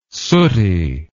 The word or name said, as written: sooty